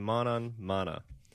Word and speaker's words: manonmana: And it's called manan mana. And my Sanskrit isn't up to date, so it's a little hard to pronounce. But how is it again? – M-A-N-O-N-M-A-N-A. So it's a manan mana.